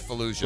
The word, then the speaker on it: Fallujah: The USS, and forgive me, I don't want to mispronounce this, but I know I am, Faluja. – How do you spell it? – F-A-L-L-U-J-A-H. – Fallujah, probably. I think it's Fallujah.